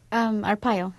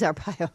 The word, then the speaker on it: Arpaio: How do you pronounce his name? – Arpaio. – It is Arpaio.